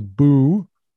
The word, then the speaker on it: Boötes: And so this one says it's pronounced so boo-o-tees. So I'm going to try to remember that and not pronounce it incorrectly. So, I think that's bow-o-tee.